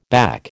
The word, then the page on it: back: American English